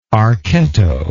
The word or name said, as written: archetto